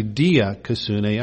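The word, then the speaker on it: dikaiosyne: is diakosune. The root word for diakosune is dikaios, or It's actually dia kasune. I always mispronounce the Greek. The root word is dia kaios, D-I-K-I-A-O-S.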